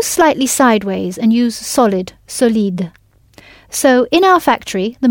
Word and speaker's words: solide: If solid is solide, with the stress at the end of the word, what would avid be? – Avid.